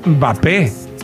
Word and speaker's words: Mbappé: Quítenle la M o la N y póngale Mbappé.